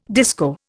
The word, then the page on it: disco: Indian